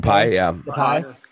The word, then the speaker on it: Depay: Depay.